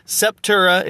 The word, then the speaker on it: Septura: It's Septura is how you say it.